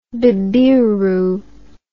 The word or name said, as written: bibiru